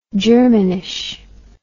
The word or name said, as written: germanish